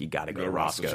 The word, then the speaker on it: Roscoe's: I hope I don't pronounce this wrong. Rocco's? – Roscoe's. – Roscoe's.